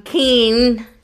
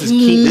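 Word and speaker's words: Keean: It's not Keenan Thompson. That's a performer on SNL.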